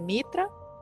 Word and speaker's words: Mitra: Mitra, no sé si lo pronuncia bien. Sí, Mitra.